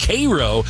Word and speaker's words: Cairo: It is pronounced Cairo.